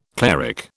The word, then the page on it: cleric: British English